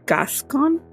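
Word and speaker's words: Gascón: But it was taken off the table following the election of Los Angeles County District Attorney George Gascon, that's how you pronounce it, who is against capital punishment.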